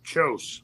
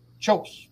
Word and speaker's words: Kjose: Kajose. I don't know if I'm saying his name right. K-J-O-S-E. Is that silent? – Chose. – Chose. Thank you.